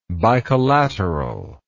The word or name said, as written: bicollateral